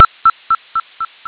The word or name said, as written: aheley